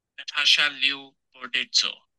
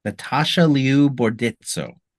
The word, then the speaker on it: Natasha Liu Bordizzo: Natasha Liu Bordizzo. – Natasha Liu Bordizzo.